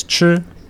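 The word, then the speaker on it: chi (吃): And to learn Chinese, it says to eat, and the way you pronounce it is chi.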